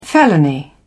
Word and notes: felony: British English

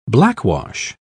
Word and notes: blackwash: British English

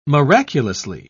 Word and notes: miraculously: British English

